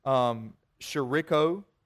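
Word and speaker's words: scirocco: If you study this, it's spelled a little bit differently than it sounds. The way you spell it is S-I-R-I-C-C-O. It's pronounced Shiriko, but it's a specific type of wind that they find in the Near East.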